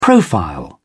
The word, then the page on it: profile: British English